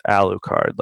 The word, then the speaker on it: Alucard: And they spell it Arucard, but they pronounce it Alucard, like with an L.